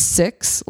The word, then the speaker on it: Sikhs: So it is Sikhism, and when you say it plural, it's six, like the number.